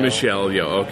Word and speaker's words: Michelle Yeoh: I call her Michelle Yeoh. – Michelle Yeoh, okay.